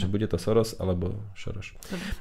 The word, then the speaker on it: Soros: A Sorožom. – A Sorožom. To je Šoroš po maďarsku.